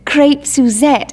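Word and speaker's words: Crêpe Suzette: You pronounce it Crepe Suzette. It's French.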